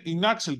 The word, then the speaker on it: Inaxel: Λοιπόν, Inaxel, καταρχήν είναι Inaxel, την προφέρω και αυτή σωστά.